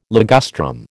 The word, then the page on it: ligustrum: American English